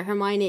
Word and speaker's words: Hermione: English author writing a book in English, it is Hermione. She in the end had to actually put a piece in the book where Hermione explains how to say her name because so many people were mispronouncing it.